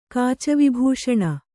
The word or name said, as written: ಕಾಚವಿಭೂಷಣ